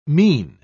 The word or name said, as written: mien